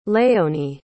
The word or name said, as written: léonie